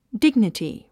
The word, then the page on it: dignity: díɡnəti; ディグニティ